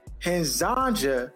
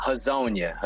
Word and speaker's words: Hezonja: Mario Hezonja. I hope I'm pronouncing that correctly. – Nah, Hezonja.